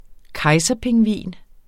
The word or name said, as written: kejserpingvin